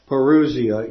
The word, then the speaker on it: parousia: But this happens to be the word parousia.